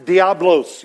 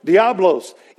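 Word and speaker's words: diabolos: In the Greek, the name is pronounced Diablos. You've heard that before. Diablos.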